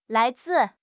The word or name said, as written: lai2 zi4